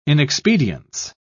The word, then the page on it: inexpedience: British English